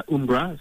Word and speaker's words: Umbra: Did I pronounce Umbra correctly, or is it Umbra? – You did. It's Umbra, so it's like an old football brand.